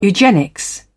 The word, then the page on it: eugenics: British English